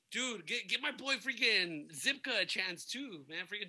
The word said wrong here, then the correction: Zabka: William Zabka. I said his name wrong last week. – Oh, that's what it is. – Zabka.